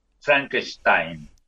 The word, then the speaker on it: Frankenstein: Este, eh, Frankenstein se pronuncia, pronuncia como lo hiciste vos, Frankenstein.